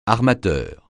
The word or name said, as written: armateur